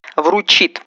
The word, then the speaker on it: вручит: Вручит. За слово «вручит» вам наград никто не вручит, потому что так говорить неправильно ходатайство.